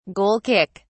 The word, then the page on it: goal kick: British English